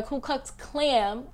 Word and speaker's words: Ku Klux Klan: So basically, out in public in San Diego, California, a white man was wearing a Ku Klux Klan.